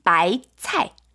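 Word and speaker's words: 바이차이 (白菜): 그래서 배추의 중국어는 바이차이.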